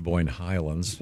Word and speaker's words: Boyne Highlands: Huge plaque at the country club in Boyne Highland? Is that where your ring is? – Where is it? – First of all, it's pronounced Boyne Highlands.